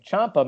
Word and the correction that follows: Ciampa: may also be, or Ciampa. I'm sorry, I don't. I hate that I pr- mispronounce his name like that. Ciampa will also be potentially a part of it.